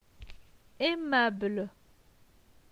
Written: Aimable

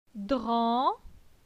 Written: Dran

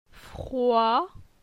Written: Froi

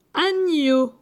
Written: Agneau

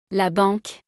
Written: la banque

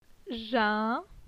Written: Jin

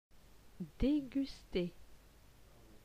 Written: Deguster

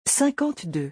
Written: 52

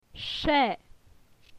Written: che

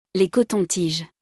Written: Les cotons-tiges

